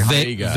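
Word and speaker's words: Wega: Okay, one of my televisions, it's a Sony Vega, I believe, a very Vega. Vega is how it's pronounced.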